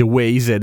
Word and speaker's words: Waze: Qualcuno di voi si ricorderà di Waze, che in realtà si legge Waze ed è un simpaticissimo gioco di parole perché Waze vuol dire anche strade.